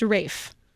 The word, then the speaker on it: Ralph: So I was going to say, if you've only seen his name written, it's spelled R-A-L-P-H, but it's pronounced Rafe.